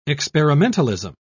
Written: experimentalism

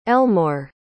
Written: Elmore

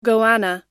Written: goanna

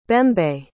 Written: bembe

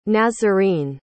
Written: nazarene

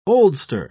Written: oldster

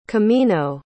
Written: comino

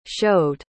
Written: showed

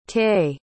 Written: taye

